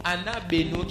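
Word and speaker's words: anabeno (ἀναβαίνω): Le mot monta vient du grec, pas de l'hébreu, j'ai fait une erreur ici, du grec, qui veut dire, qui se prononce anabeno, qui veut dire élever ses pensées.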